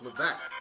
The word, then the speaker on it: Levesque: They said Levesque, and I'm like, no, it's Levesque. You don't pronounce the S.